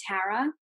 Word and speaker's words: Tara: My family actually pronounces it with a hard A. They call me Tara.